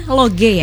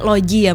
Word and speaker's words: Loge: Dari awalnya Logi, tapi ini tetap bacanya Loge ya. – Loge gitu. – Oke, bukan Loji ya, Mbak, bukan.